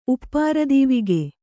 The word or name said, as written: ಉಪ್ಪಾರದೀವಿಗೆ